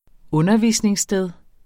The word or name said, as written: undervisningssted